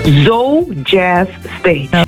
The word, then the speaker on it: Zo Jazz Stage: Hoe spreek je het zelf uit? Zuidoost Jazz Stage of Zo Jazz Stage? – Zo Jazz Stage.